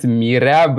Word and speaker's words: Mirea: It's like the stress is on the first syllable. So it's Mirea, but it was like the best anyone and any non-Romanian has ever done.